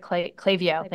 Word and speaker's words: Klaviyo: Klaviyo and, did I say that right? Klaviyo, thank you, and Instacart.